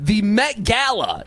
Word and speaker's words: Met Gala: The Met Gala, is that how you say it? – Yes.